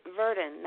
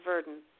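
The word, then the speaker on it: Virden: So it's spelled like it sounds, but it's an I at the beginning of Verdon.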